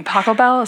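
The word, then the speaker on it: Pachelbel: Paco Bell, is that how you pronounce yes. – it? In Canon D? – Yeah.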